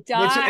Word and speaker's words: Dai: And I know sometimes it's pronounced Day, but sometimes it's also pronounced Die. So we'll go with the Welsh pronunciation. But no, you're correct. It is pronounced both ways, but I usually pronounce it my Welsh form.